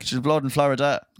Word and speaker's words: Flo Rida: His name is spelled Florida.